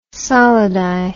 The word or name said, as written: solidi